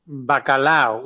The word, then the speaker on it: Bacallao: I'm afraid I've failed in finding some music by the famous Cuban cabaret singer Juana Bacallao. Is that how do you pronounce it? – Bacallao, which also bizarrely is the word in Spanish for salted cod.